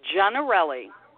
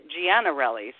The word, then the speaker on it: Giannarelli: Although I will say that my sister pronounce it, pronounces it Giannarelli. So I answer to pretty much anything, but Laura Gianarelli in Italian, which is what my heritage is, you say Gianarelli, but I just answer to Gianarelli.